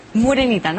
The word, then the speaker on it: Morenita: Morenita. – ¿Verdad? – Morenita, ¿no? Así se pronuncia. – Muraneta, bueno, casi.